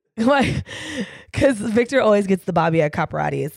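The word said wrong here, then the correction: Capriotti's: And am I saying that right? – Capriati's. – Capriati. That's what I said, right? – No, but we keep going. – Did I forget the pre? – You forgot a lot of things. – Might be true. Capriotis. How do you say it? – Capriotis. – Capriotis. You want me to roll it? – There you go.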